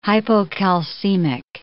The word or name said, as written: hypocalcemic